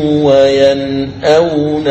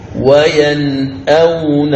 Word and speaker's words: ينأون: يعني أن الإظهار مع الهمزة لا يوجد لورش إلا في هذه الكلمة وهي كلمة ينأون، فلاحظ هنا عند النطق بها تظهر النون الساكنة وتظهر الهمز فتقول وهم ينهون عنه وينأون عنه وينأون عنه. لاحظ يعني ذكرت النون الساكنة ثم ذكرت بعدها الهمز وينأون عنه، هذا يسمى الإظهار. وهذا هو المثال الوحيد لورش في الهمز.